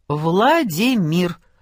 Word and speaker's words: Владимир: Владимир с ударением на мир».